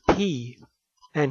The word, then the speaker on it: Peay: His actual name was Benjamin P.A., spelled P-E-A-Y, and most people pronounce it P, and he finally gave up later in life, and he pronounced it P also, but he did tell people who asked that it was supposed to be pronounced P.A.